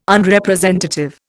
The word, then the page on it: Unrepresentative: Indian